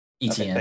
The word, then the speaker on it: Etienne: And well, he didn't have his first round running back, who we always played with in college, Travis Etienne, or however you pronounce his last name. Sorry, I'm totally butchering that. Etienne? – Etienne, okay, yeah.